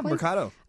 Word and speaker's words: Mercado: Mercado, did I say that correctly? – Yeah, Mercado.